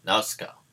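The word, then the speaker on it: Nausicaä: I'm halfway through Nausicaa. – Yeah. – It's really good. – Nausicaa. Obviously, that's how they say it in the film, so I presume that's how you say it.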